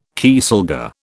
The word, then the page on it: kieselguhr: British English